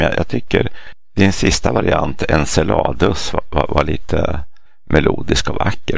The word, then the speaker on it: Enceladus: På engelska så säger man Enceladus med ett S-ljud. Men det grekiska ordet, när månen är namngiven efter en av giganterna i den grekiska mytologin. Och där är jag ganska säker på att ordet ska uttalas Enceladus. Med K, det skrivs med kappa.